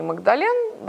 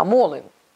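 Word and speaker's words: Magdalen: Потому что правильно, кстати, произносить не Магдален, да, не, не колледж Магдалена, а Молин, Молин колледж.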